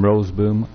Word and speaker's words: Roseboom: John, last name Roseboom, R-O-S-E-B-O-O-M.